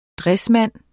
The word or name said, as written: dressmand